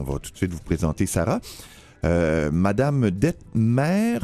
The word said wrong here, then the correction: Dettmers: Sarah, est-ce que c'est comme ça que je prononce votre nom de famille? D-E-T-T-M-E-R-S, Detmer? – On l'appelle bien, c'est Detmers. – Detmers, bon, merci.